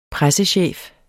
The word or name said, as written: pressechef